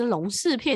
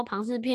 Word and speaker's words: 庞氏: 后来发现他因为他旁是哦，不是马氏骗局，是龙氏骗局在讲什么？因为他旁不会念，他上面那个宝盖头他不知道。他不知道要怎么念，然后他就我就跟他说，庞氏骗局是在讲什么？